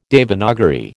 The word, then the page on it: Devanagari: American English